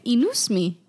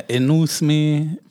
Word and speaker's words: INOUCME: I say inusme. Yeah. – Yeah, inusme.